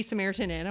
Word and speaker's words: Samaritan Inn: I want to be Samaritans. – I know. Inn. That's the way I've... – Multiple Samaritans. – I'm admitting I have said that. I've done apostrophe S. You know what I'm saying? – Yes. – And so I think I've always said it wrong.